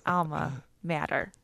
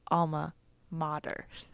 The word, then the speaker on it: alma mater: I was like, oh, at my alma mater. And I came home and my parents were listening and my mom's an English teacher. She was like, honey, it's not alma mater. It's alma mater.